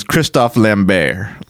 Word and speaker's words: Christophe Lambert: His the true pronunciation of his name is Christophe Lambert. It is, but the American version is Christopher Lambert.